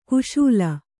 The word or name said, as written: ಖುಶಾಲ್